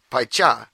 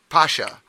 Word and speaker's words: Pacha: In Portuguese, it's spelled P-A-C-H-A. In Portugal, my friend says it, that's their family, Pai Cha. And you go to America, they call it Pacha. In some places, they call it Pasha.